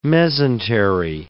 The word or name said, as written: mesentery